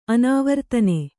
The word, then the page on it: ಅನಾವರ್ತನೆ: anāvartane